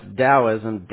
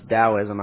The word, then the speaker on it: Taoism: Oh, yeah, it's pronounced Taoism, Tao, or what most people call it, Taoism. But it is pronounced Taoism. I don't know why, but it's pronounced that way.